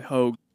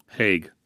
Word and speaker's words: Hague: It's probably Hogue. – Hague. I was wrong on both counts. It's the Hague.